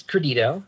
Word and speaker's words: Credito: It's Credito. – All right. – You know, with I guess the second Credito, yeah, the second there, the T is more like a D, and right.